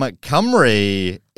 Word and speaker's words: Cymru: From Strachan lice in, and how do you say Wales in Welsh? From Cymru, it is James Burton.